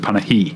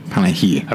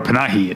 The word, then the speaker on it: Panahi: I saw Three Faces where they say his name a million times, so I have to remember it's Panahi. – Panahi. – I've always said Jafar Panahi, it's Panahi.